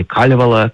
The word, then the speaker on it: Калевала: Калевала или Калевала, как говорят местные, с ударением на первый слог.